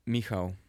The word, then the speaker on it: Michal: I want to make sure I'm saying your name right, Michal. – Almost. Michal. – Michal. Oh, that's better.